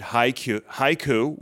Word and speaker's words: HYCU: So the presenters yesterday, we had Haiku, which is spelled H-Y-C-U, making it a true acronym and not an initialism.